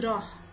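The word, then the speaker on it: راح: کلمه اول رو نگاه کنید، راه با هجیمی، تلفظ واقعش اینه، ولی ایرانی ها فارسی زبان ها چون هی جیمی ندارن مثل هی گرد تلفظش میکنیم.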